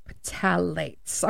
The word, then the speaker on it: Phthalates: And I normally say that wrong. Phthalates. Phthalates. I'm pretty sure I did it right this time.